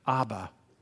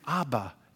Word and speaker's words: Abba: In fact, say it, Abba. It sounds like dada in English, just phonetically easy to say.